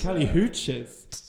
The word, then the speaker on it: Kali Uchis: It was After the Storm by Kali Uchis. – Who? Kali Uchis? – I think I pronounced that right. – Kali Uchis? – Kali Uchis.